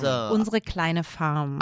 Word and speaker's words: Unsere kleine Farm: That was Unsere kleine Farm.